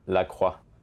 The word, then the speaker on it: La Croix: It's pronounced La Croix.